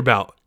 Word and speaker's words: Bout: This one is going to be about Victor Bout, or Butt, I guess it's pronounced in Russian.